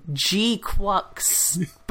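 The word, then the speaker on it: GQuuuuuuX: I prefer calling it G-Quax. – Well, that's not how they pronounce it in the movie. – It's supposed to be Quax, yes. But, like, the Quax. It's G-Q-U-U-U, like 6 U's.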